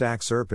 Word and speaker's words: AXRP: This podcast is called AXRP, pronounced axe-urp and short for the AI X Risk Research Podcast.